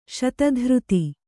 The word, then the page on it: ಶತಧೃತಿ: kannada